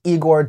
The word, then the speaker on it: Igor: This is Igor, pronounced Igor.